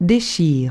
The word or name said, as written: dechire